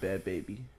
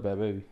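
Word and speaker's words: Bhad Bhabie: is said bad baby. That's what they call her. I know that's how it's spelled, but they call her bad baby.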